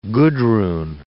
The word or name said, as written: Gudrun